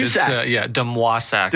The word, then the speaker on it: DeMoisac: DeMoisac. – DeMoisac. – Yeah, like Patrick Waugh, DeMoisac.